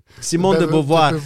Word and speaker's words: Simone de Beauvoir: Eh, io capisco la borghesia, ma Baustein, Simone de Bois, Beauvoir, non so neanche come cazzo si legge, Be- Be- Be- Beauvoir, Beauvoir, Simone de Beauvoir.